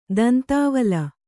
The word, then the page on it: ದಂತಾವಲ: kannada